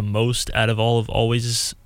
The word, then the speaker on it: Alvvays: I'm going to start off with this band, Always. It's Alvvays. They have a lot of popular songs, but I think they're pronounced Always. I'm pretty sure it's just spelled with two V's.